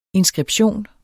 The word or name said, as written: inskription